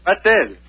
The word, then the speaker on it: Batel: Battelle. – But how do you spell that? – B-A-T-E-L.